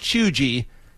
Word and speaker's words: cheugy: It's spelled C-H-E-U-G-Y, but it's pronounced choogee.